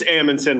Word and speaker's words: Amundson: It's Amundson, like Anderson.